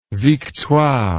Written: victoire

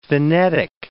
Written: phenetic

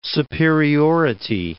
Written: superiority

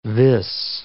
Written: vis

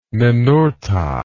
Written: ninurta

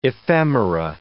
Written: ephemera

